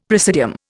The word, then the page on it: praesidium: Indian